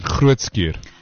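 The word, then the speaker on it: Groote Schuur: So they raise funds to reduce the waiting time for surgery by covering costs for additional theatre time and nursing staff at Grootskuur. Did I say that right? – You did Grootskuur.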